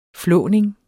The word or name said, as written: flåning